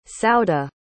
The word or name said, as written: sauda